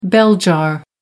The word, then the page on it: bell jar: American English